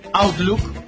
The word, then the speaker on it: Outlook: Se escribe O, pero se pronuncia A. Outlook.